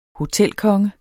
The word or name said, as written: hotelkonge